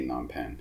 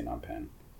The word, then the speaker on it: Phnom Penh: How do Cambodians say Phnom Penh? Try to stack the P on top of the N sound. Phnom. – Phnom Penh. – Phnom Penh. – Phnom Penh. – Phnom Penh.